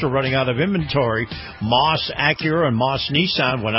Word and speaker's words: Maus: Moss has one of the largest selections anywhere in the state. And before you buy elsewhere, see Moss. Now, Moss is spelled M-A-U-S.